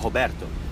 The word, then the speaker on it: Roberto: Is, yeah, my real name's Roberto, and I'm half Brazilian, and when Roberto is pronounced in Portuguese, it sounds like Roberto.